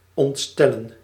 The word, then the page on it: consterner: France; [kɔ̃s.tɛʁ.ne]